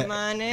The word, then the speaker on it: Monat: Yeah, because when I was doing my notes, it's like one of the first things I wanted to ask you is like, how do you pronounce it? – Is that why it's called like that? Like Monate. – Yeah, Monate.